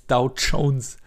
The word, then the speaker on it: Dow Jones: Und das Schlimmste bei den Börsennachrichten, ich weiß nicht, wie dieser Heini heißt, der immer die Börsennachrichten sagt, der sagt immer der Dow Jones. Jones. So als wäre es mit T-S-C-H-O-U-N und dann 4S. Dow Jones. Jones. Das wird Dr. Jones. Das wird einfach soft ausgesprochen.